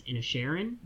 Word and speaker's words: Inisherin: I think it's Inisherin? – Yes.